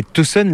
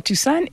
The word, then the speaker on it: Tucson: et Tucson, la ville, alors il y a toute une partie entièrement plate, et ça, c'est la ville de Tucson. – Ça, c'est la ville du Tucson. – Tucson, comme on dit en France.